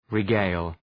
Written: regale

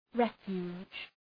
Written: refuge

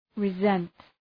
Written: resent

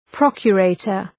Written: procurator

